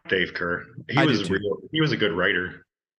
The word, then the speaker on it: Kehr: Yeah, I think it's spelled K-E-H-R, but you pronounce it Kerr.